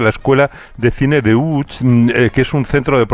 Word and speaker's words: Łódź: Aunque se pronuncia Łódź, se escribe Łódź.